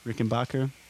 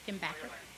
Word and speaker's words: Rickenbacker: Rickenbacker, the one in the tune. – How do you say it? Rickenbacker. – Go back and forth. – Yeah. It's like potato, potato in this band.